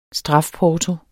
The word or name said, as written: strafporto